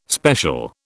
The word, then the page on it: special: British English